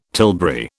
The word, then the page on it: tilbury: British English